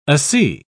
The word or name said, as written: asea